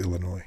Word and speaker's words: Illinois: There's no, it's Illinois. There's no, it's a silent S.